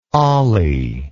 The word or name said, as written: ollie